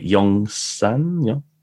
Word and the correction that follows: Yonsei: Yonsei. Sorry, I always get that wrong.